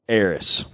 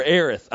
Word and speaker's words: Aerith: Eris. – Yeah. – Or Aerith, I'm sorry. So for the hardcore nerds out there, there is two pronunciations of her name, Eris and Aerith.